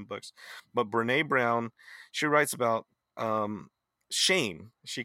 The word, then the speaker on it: Brené Brown: Brené Brown is like Rene, but with a B in front.